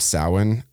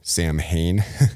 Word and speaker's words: Samhain: It also happens to fall on the same time as a pagan holiday called Samhain, which is actually spelled Samhain. It's kind of weird. It's not, I don't know how the pronunciation came about, but that was a Celtic festival, a pagan festival on October 31st.